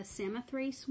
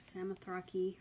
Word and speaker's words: Samothrace: I think that's how you say it. I'm sure in Greek it's like Samothrake or something like that, but I found Google pronunciations and they all say Samothrace, so it's the English version.